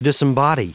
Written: disembody

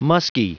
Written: muskie

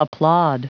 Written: applaud

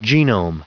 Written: genome

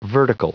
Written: vertical

